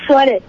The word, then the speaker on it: Swarit: This is Swarit. – Could you spell that? – S-W-A-R-I-T. – Swarit.